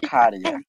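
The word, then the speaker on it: Käärijä: Caria. Así es como se pronuncia.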